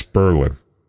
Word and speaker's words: Berlin: Not to be confused with Berlin. In New Hampshire, yes. It's Berlin. – Mm-hmm. – Spelled the same. – Get it straight. but said different.